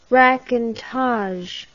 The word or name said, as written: racontage